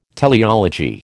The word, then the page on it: teleology: American English